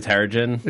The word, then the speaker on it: Terrigen: I think we're gonna get some Tarragon mist. – The Terrigen.